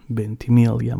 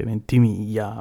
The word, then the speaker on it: Ventimiglia: So che avete storto il naso quando ho detto Milo Ventimiglia, ma si pronuncia così in inglese, anche lui lo dice sempre. In realtà lui ha origini italiane, quindi il cognome sarebbe Ventimiglia, ma lo chiamano così.